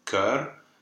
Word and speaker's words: cœur: Yes, like the you know, a very common word, the word for heart, cœur, that is not a silent R. You do pronounce it.